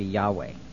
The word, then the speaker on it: Yahweh: which is J-H-V-H, mm-hmm. – Or the Hebrew pronunciation would be Yahweh.